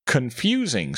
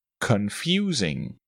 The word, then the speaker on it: confusing: Confusing starts with a schwa sound, then a long U sound, and a short I sound. Confusing.